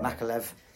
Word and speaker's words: Makhachev: But what I was going to talk about, so the headline fight, Charles Dubronx Oliveira versus Islam Makhachev. I think my pronunciation. – Second time we're Makhachev. – Right.